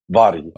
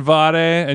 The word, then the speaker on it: Vari: I pronounce my name like Vari 2TM. That's how you say it in Sweden. It's like V-A-R-I almost. Vari. – Okay. So I said Vare, and so it's Vari, but I said Vare.